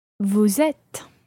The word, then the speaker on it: êtes: Vous êtes.